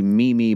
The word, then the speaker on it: Meme: His dog's name is Mimi. However, I thought, because it is spelled M-E-M-E Boyd, I thought it was Meme Boyd.